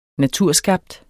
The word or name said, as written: naturskabt